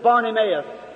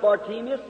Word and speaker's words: Bartimaeus: We know him in the Bible as Bartimaeus. Many pronounce it Bartimaeus. Either way will be all right.